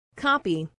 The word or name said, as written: copy